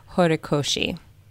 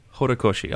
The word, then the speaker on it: Horikoshi: No, it's Kohei Horikoshi. – Horikoshi.